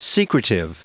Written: secretive

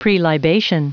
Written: prelibation

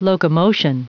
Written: locomotion

